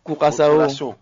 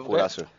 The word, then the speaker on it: Curaçao: Professeur, vous êtes en train de parler de Curaçao. – Quoi? Comment tu prononces? – Curaçao. – Curaçao. – La vraie expression, c'est Curaçao.